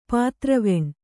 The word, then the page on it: ಪಿತೃವನ: Kannada